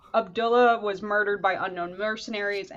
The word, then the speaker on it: Abdullah: No, no, Abdullah.